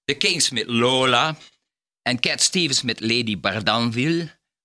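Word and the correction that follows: Lady D'Arbanville: Zeg ik dat goed, Jos? – Lady Darbanville. – Darbanville, juist, ja.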